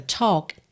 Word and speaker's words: talk: E o L dessa palavra talk também não é pronunciado.